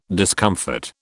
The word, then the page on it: discomfort: British English